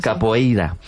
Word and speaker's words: capoeira: Pues todos sabemos lo que es la copeira, ¿verdad? Capoeira. Qué mal pronuncio últimamente.